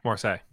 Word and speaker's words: Marseille: so that was worth it for Marseille. Is that how you pronounce it? – Yeah, Marseille.